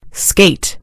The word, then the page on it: skate: British English